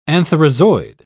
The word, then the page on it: antherozoid: British English